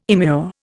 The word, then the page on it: immure: Indian